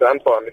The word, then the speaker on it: Anton: I'm guessing it's spelled A-N-T-O-I-N-E? – No, for some reason you just assume it's Antoine. It's Antoine. A-N-T-O-N.